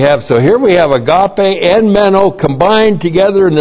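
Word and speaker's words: agape (ἀγάπη): And then we have John's other favorite word, agape. Hey, agape. A-G-A-P, long E. Agape, this is virtue love.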